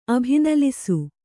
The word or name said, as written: ಅಭಿನಲಿಸು